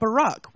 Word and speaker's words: Barack: I mean, he's called Barack, which rhymes with Iraq, and his middle name's Hussein.